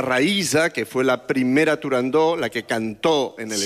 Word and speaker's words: Turandot: Turandot. – Turandó. – Turandot. – No. – Turandó. – No. No se dice, no se debe pronunciar Turandot. – No, Turandó. – La ópera se titula Turandó.